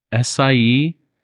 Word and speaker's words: Esahi: I got Esahi.